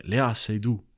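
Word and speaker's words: Léa Seydoux: ja selle Lea Seydoux, ma ei tea, kas ma hääldan tema seda. – üsna õigesti minu arust, jah.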